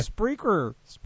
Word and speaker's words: Spreaker: And now on SoundCloud and Speakra. Did I say it right? – Spreaker. Where do you get the A at the end? – I don't know, man.